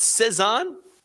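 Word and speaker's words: Cézanne: JT Post Cezanne. – That's right. You pronounced it right.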